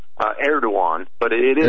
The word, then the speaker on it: Erdogan: But yeah, they pronounce it in Turkish. I always say, just think it Erdogan. – Rhymes with say Erdogan Ben Kenobi. If you're a Star Wars fan, it rhymes. – All right. – It rhymes with Obi Wan.